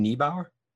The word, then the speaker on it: Niebauer: Chris, how do you say your last name, Niebauer? – Niebauer, yeah. – Chris Niebauer, thank you so much for coming on, author of No Self, No Problem.